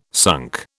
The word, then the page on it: sunk: British English